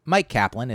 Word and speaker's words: Myq Kaplan: So Mike Kaplan is my name,